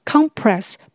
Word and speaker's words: compress: You may try a warm compress. 你或许可以试试热敷哦。 May 就是或许、可能。 Try 试试看。 A warm compress 就是指热敷。我们中文说的热敷，英文是用 warm 温暖的这个字。 Compress 本身是指按压，它可以当动词，可以当名词。这里是一个名词，重音节在前面要念成 compress。